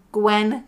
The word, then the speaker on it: Gwen: But I have one just under my name, Gwen Juvenile. And I'm going to spell it because that's how I am. G-W-E-N, and then Juvenile is J-U-V-E-N-A-L.